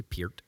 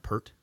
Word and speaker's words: Peart: Did you say Peart? – Peart. – Is that how you pronounce it? – Yeah. – It's not Pert, like the shampoo. – Not from what I understood. I called it Pert before, and they were like Pert. Peart. – Peart.